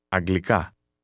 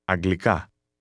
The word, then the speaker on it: Αγγλικά: Αγγλικά. – Did you notice that the speaker stressed the last part of that word? Once again, say English. – Αγγλικά.